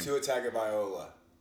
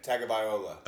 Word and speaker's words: Tagovailoa: How do you say his name? – Tua Tagovailoa. Tagovailoa. – Is that real? – Yes. – Say it again. – Tagovailoa.